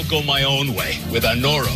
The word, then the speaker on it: Anoro: Anoro treats it's pronounced Anoro.